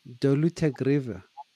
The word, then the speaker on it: dolutegravir: dolutegravir.